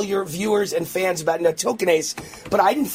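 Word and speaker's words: nattokinase: And he stayed healthy by taking, he sent this to me yesterday, natokinase.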